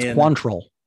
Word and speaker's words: Quantrill: I can never say his name. Quantrill. – Yes, it's Quantrill.